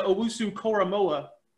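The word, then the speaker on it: Owusu-Koramoah: Give me Joke, Jeremiah Owusu-Koramoa. – I like how he pronounced it properly.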